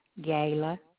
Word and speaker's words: Gayla: Gayla, G-A-Y-L-A.